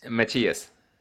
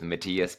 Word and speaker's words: Matthias: Yeah, the French way is Matthias, which nobody can really say in English-speaking countries, so I Americanized it a little bit with Matthias, but Matthias is how they say it in Scandinavia, places like that, you know, so, or even Germany. My mother's Dutch, so in Holland they say Matthijs, but yeah, I just go with Matthias.